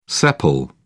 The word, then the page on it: sepal: British English